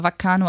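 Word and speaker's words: bacano: Geschrieben mit B, aber Vaccano ausgesprochen.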